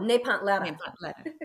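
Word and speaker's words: Nepantla: So people like move the L to the wrong place. – Okay. Will you say it again so I can get it correctly? – Yeah. Napatla. – Napatla. Napatla. Yeah. Now you've described yourself as that. – Oh, Napantla. – Yes.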